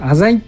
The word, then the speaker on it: 浅井: これ、浅井って読むんですよね。これあの、これも古典ラジオで知りました。浅井です。浅井じゃないです。